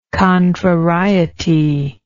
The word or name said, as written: contrariety